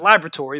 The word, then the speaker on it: laboratory: Turns out it's laboratory, but it's the British pronunciation.